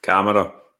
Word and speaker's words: Kamara: So the only thing that's pissing me off is that the BBC commentators can't say his name right. – Kamara. – Oh, mate, honestly, how hard is it to say Kamara?